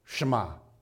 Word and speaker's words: Shema: Now, this command that we just read is called the Shema. Or if you say it like you're Jewish, with like Hebrew flair, it's like Shema right? Falafel, right? Shema.